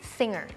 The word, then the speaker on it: Singer: จริงๆแล้วต้องออกว่า The Mask Singer เราจะไม่ออกเสียงเก้อเราจะออกเสียง Singer จะมีคำในภาษาอังกฤษหลายๆคำที่เป็น NGER Inger แต่ว่ามันออกเงอไม่ออกเกอร์